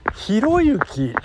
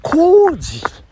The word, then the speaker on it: 浩之: えっ、ー、と、ひろゆき、えー、ひろしのこれと書いて、ひろゆきと書いて、えー、こうじさんとお読みするということでございますね。